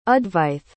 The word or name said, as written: advaith